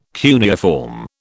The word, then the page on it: cuneiform: British English